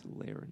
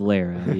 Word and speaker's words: Lara: Uh, it's Lara. It Laura. – is Lara. Yeah, uh, she's correct. Lara Croft, and I was like, it's spelled like Lara Croft, and that's just what's been in my mind.